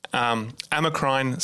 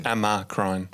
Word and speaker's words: Amacrine: Amacrine cell. – Amocrine. – Amacrine. – Amacrine. Spell it for me. – See, I would have thought A-M-A-C-R-I-N-E.